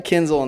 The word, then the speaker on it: Kinzel: Is that how you say your last name? – Yep. – We got Big Zach Kinzel in the house.